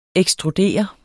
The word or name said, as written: ekstrudere